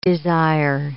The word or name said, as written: desire